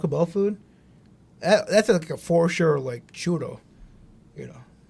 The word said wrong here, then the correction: chorro: Did I say that right? Diarrhea in Spanish is churro. – Yo, chorro. – Chorro. – Chorro. – El chorro. It sounds funny. Like Zorro, but like a like a shitty Zoro.